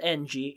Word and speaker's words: Ng: It's spelled NG. It's pronounced Ng, ING.